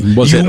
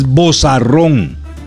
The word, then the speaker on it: vozarrón: Se dice vocerrón, ¿verdad? – Vocerrón. – No se dice vocerón, no. – Puedes usar los dos términos, pero digamos que el término es. Digamos que el término. Y es más, es vozarrón.